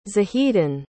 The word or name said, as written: zahedan